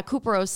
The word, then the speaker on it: couperose: It's spelled C-O-U-P-E-R-O-S-E. – So it's kind of like Cooper. – Yeah, Cuperose Skin.